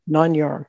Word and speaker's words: Nāṉ Ār: Nāṉ Ār.